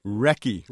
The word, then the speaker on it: Recce: Recce. – Recce, right. As in a reconnaissance? – Exactly.